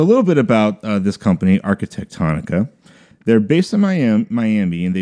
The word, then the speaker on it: Arquitectonica: But the design of the hotels, that was done by a company called Arquitectonica. – Jeez. – Another word that rhymes with harmonica. – Yeah, say that five times Arquitectonica.